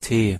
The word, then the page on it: t: German